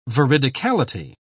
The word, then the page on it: veridicality: British English